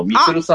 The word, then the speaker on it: 326: あの、イラスト見ていただいたらわかると思うんですけど、ミツルさんです。 – これは 326 と書いて、ミツル先生の。